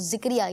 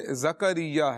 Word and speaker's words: زکریا: ویسے زکریا ہے، زکریا، زا کے اوپر جو ہے زبر ہے، زکریا، یہ اس کا درست تلفظ ہے۔